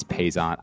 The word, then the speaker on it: Paisant: It's P-A-I-S-A-N-T, and it's Paysant. I think it's pretty easy,